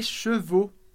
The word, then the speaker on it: chevaux: E aqui atenção para a pronúncia de chevaux. Chevaux, cavalos. Um cavalo, a gente diz cheval, un cheval, um cavalo. Os cavalos, nesse caso, les chevaux, o no final.